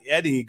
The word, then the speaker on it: Edey: Is it Edey or Eddie? Do you know how to say it? – You can pronounce it either way.